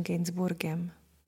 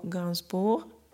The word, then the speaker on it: Gainsbourg: Francouzsky by se to jeho příjmení mělo vyslovovat nějak podobně jako Gainsbourg, ale často ho slýchám i v té anglické verzi Gainsbourg a tak doufám, že vám nebude vadit, když tuhle verzi budu používat.